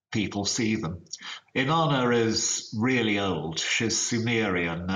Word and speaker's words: Inanna: I'm going to pronounce this horribly, but is it Inanna? – Yeah, she's the oldest and best recorded of them.